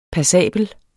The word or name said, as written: passabel